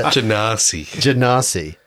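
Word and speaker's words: Genasi: Ganassi. – A Ganassi. Ganassi. Oh, that's right, because I kept playing it. He's an Ganassi. – Ganassi's getting Ganassi. – Emphasis on the nah.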